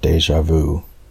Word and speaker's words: DjVu: Next up is DJVU, pronounced as the French word déjà vu.